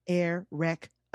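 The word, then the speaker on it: Airwrecka: It's spelled A-I-R as in air, and then W-R-E-C-K as in rec with an A. Erica. Erica.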